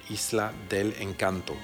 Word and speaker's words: Isla del Encanto: Elle a été remplacée par la musicienne d'origine argentine Paz Lenchantin, qui, elle, par contre, plus à cheval sur la prononciation hispanique, paraît-il, oblige désormais Black Francis à chanter Isla del Encanto.